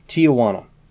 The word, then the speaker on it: Tijuana: Her debut was Herb Alpert and the Tawana Brass. – It's Tijuana Brass. – Tijuana. I've always been mispronouncing.